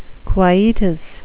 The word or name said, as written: quietus